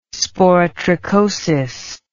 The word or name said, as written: sporotrichosis